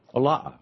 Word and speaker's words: Ola'a: How do you say that? – That's Ola'a. – Ola'a, okay.